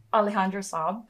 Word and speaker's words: Alejandro Saab: We are joined by the incredibly talented voice actor, Alejandro Saab.